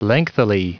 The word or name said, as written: lengthily